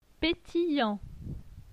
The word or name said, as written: pétillant